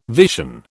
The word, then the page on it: vision: British English